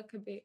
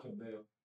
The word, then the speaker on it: Cabello: Like Camilla Cabello. How do you say her name, the singer? – Camilla Cameo. Cabello. – Cabello. This is Camilla Cabello. – Not pronounced in Spanish.